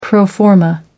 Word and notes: pro forma: American English

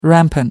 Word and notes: rampant: American English

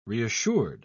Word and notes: reassured: British English

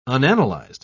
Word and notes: unanalyzed: British English

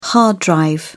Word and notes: hard drive: British English

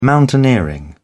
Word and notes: mountaineering: British English

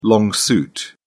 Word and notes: long suit: British English

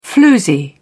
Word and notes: floozy: British English

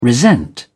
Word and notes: resent: British English

